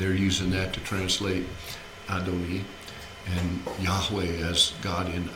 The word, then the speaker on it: Adoni: Adonai is how most English people speak and sing, but the letter in the Hebrew is actually pronounced like a long E instead of an I, not Adonai. It's pronounced because of the punctuation Adoni or Adoni, like that.